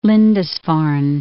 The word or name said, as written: Lindisfarne